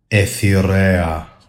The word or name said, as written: AITHEREA